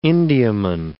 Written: Indiaman

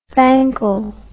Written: fankle

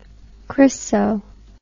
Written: chryso

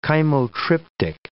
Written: chymotryptic

